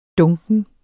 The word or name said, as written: dunken